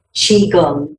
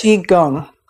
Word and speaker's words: Qigong: Qigong, yes. – Qigong.